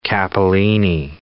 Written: capellini

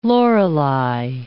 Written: Lorelei

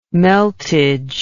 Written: meltage

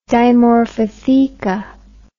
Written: dimorphotheca

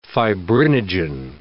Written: fibrinogen

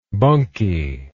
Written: bunkie

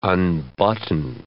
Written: unbutton

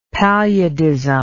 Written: paludism

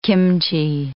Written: kimchee

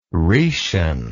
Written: rhetian

